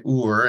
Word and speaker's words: Hur: And that year, essentially, this boy, Ur, and his name is spelled H-U-R, but like Ben-Hur from the movie.